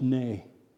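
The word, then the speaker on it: nay (נא): It's just a little word spelled N-A in our language. It's pronounced nay.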